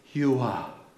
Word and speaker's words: Yahweh: And here's another correct pronunciation of Yahweh. It is like breathing. It is Yuah.